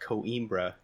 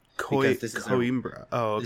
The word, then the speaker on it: Coimbra: Now, I will say that this game is actually pronounced Coimbra. – Coimbra. – This is a real name of a real city in Portugal.